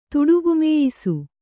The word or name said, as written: ತುಡುಗುಮೇಯಿಸು